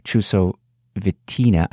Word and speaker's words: Chusovitina: but yet we're seeing athletes like Oksana Chusovitina, I think I'm pronouncing that right, from Uzbekistan. – Yes, that's right. – Is that right? Okay, good. Well, she interestingly enough, about this athlete, Oksana Chusovitina, she's officially competed in 8 Olympic Games.